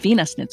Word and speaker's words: Wiener: And Wiener is how the Germans would pronounce it. So they pronounce the W's as a V.